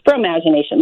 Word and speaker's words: Fromagination: As in F-R-O, imagination, almost like imagination, but it's fromagination, like fromage.